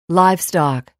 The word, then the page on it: livestock: láivstɑ̀k